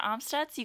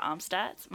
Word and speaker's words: Amstutz: And I'm Sandra Amstutz. You can find me on all platforms at Sandra Amstutz.